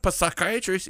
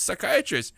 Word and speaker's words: psychiatrist: I said, it's not psychiatrist. It's psychiatrist. They said, the P is silent.